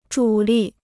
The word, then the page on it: 主力: zhǔ lì